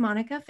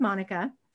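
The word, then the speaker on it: Monika: So the Sexploration with Monica Facebook page. And my name is spelled a little bit differently. So it's M-O-N-I-K-A. So Sexploration with Monica.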